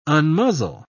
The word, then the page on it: unmuzzle: British English